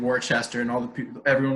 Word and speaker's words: Worcester: Yeah, you get to Worcester, and you get to, but when you're in Worcester, that's the only R you're gonna have pronounced when you're there. Yeah. Is the R Worcester.